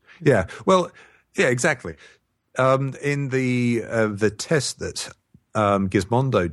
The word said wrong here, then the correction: Gizmodo: did, or Gizmodo, I always mispronounce that.